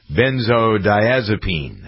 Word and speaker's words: benzodiazepine: I just was talking to him off the air about how to pronounce benzodiazepine.